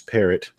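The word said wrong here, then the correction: Pierrot: wanted to experiment. – Piero. – Thank you. I've been wondering how to pronounce that for a long time.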